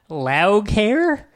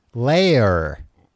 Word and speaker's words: Laoghaire: Well, the first auto thing that comes up is how to pronounce loud care. Let's tune in. Layer.